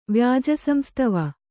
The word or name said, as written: ವ್ಯಾಜಸಂಸ್ತವ